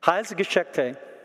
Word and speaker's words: Heilsgeschichte: And the word is Heilsgeschichte. Am I pronouncing it correctly? She said, Heilsgeschichte.